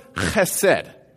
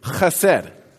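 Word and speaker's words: chesed (חסד): And it's pronounced chesed. Chesed. Say that with me. Chesed. Again, chesed.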